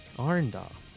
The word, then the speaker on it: Orindal: How do you say this? Orindal? O-R-I-N-D-A-L? – Arendal? – Arendal.